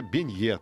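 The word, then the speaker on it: beignet: Французские бенье, тут все понятно, в конце у нас ударение падает. Пончики без какой-либо начинки традиционно подают к кофе.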